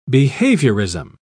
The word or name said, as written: behaviorism